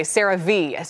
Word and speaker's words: CeraVe: CeraVe.